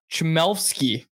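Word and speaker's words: Chmelevski: Uh, Chmelfsky is how it's pronounced.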